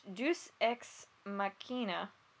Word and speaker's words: Deus Ex Machina: It's pronounced Deus Ex Machina. – Yeah, But it looks like Deus Ex Machine. – Yeah. – This is Latin.